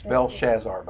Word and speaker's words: Belshazzar: It's Belshazzar, by the way, is the way you pronounce that. – That very night, Belshazzar, the Chaldean king, was killed.